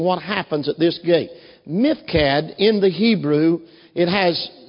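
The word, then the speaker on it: Miphkad: Some Hebrews pronounce it Mifkad, but in our English pronunciation we call it Mifkad. You ought to learn to say that word, Mifkad.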